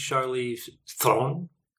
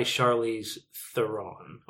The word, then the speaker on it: Charlize Theron: So it technically is pronounced Charlize Theron. – That was really good. – With a rolling R and everything else squashed into one syllable.